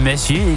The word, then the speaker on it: Messier: Dus als je het helemaal wil uitspreken, dan is het dan Messier.